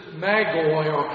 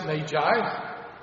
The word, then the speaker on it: magi: As Matthew wrote his gospel, he describes these visitors as magoi or magi. We pronounce it magi.